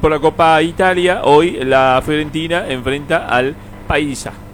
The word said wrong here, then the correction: Pisa: o al Pisa, perdón, Pisa, no es Paisa, al Pisa, que no se escribe con Z, no es como pizza de pizza de comer. – Claro, no, es Pisa. PISA.